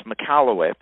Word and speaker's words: Michalowicz: We'll have a link in the show notes, but it's Michalowicz.